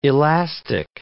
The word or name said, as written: elastic